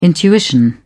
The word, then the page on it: intuition: British English